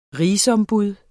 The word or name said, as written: RIGSOMBUD